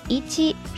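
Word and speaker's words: いち: 日语数字的一读作一七，是源于中文汉字的读法，我们说音读 on y o m i d i s 呢。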